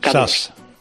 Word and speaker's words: Zaz: Zaz. – Zaz. Bueno, pues a mí me resulta muy difícil. – A ver, hago una Z, no la categórica castellana, ni siquiera la S líquida.